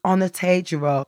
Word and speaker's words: Onatejiro: I remember in nursery, for example, my mum coming into nursery, teaching the nursery teacher how to pronounce my name, and she spelt it out phonetically. She's like, it's Onatejiro.